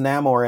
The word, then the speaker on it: Namor: Well, this version is Namor, and they give a reason why he's pronounced that way. Because it's Mayan culture.